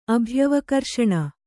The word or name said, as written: ಅಭ್ಯವಕರ್ಷಣ